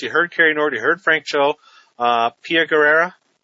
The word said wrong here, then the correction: Guerra: I hope I've said her name right. – Guerra, there you go.